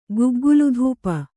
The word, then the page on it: ಗುಗ್ಗುಲುಧೂಪ: guggulu dhūpa